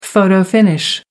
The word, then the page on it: photo-finish: American English